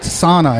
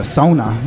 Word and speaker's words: sauna: Yeah, it's not sauna. It's a sauna. You know, that's the correct pronunciation, you know, from Finland, you know.